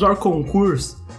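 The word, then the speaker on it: hors concours: Eu tô pronunciando errado? – Não, Orconcours, tá certo. – É, em francês... – Como que pronunciaria o Juninho Pernambucano? – É... – Orconcours! – Ah, agora eu entendi. Agora faz mais sentido, eu imagino que essa deve ser a pronúncia correta.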